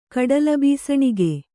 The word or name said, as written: ಕಡಲಬೀಸಣಿಗೆ